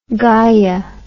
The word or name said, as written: gaiaist